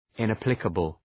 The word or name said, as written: inapplicable